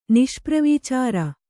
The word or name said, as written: ನಿಷ್ಪ್ರವೀಚಾರ